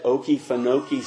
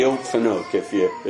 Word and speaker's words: Okefenokee: And if you came to Baker County and you said Okefenokee Swamp, we'd know you weren't from around there. It's the Okefenokee, if you want to know the proper way to say Okefenokee.